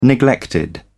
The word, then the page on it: neglected: British English